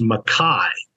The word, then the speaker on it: McKay: And actually the way the Scots pronounce it is McKay.